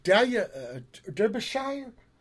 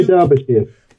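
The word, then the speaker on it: Delia Derbyshire: But yeah, so where the sound comes from, yeah, it's like, God, what was her name? Dahlia Derbyshire? – This lady? Dahlia Derbyshire. – Oh, okay. Yeah, my bad pronounce, sorry.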